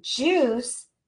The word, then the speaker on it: juice: And so here in this word, juice, the I is going to be silent.